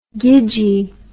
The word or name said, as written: gidgee